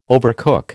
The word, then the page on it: overcook: American English